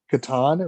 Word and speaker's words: Catan: It's actually pronounced Catan, if I'm correct. Yeah. I was correct. – I call it Catan, once. But okay.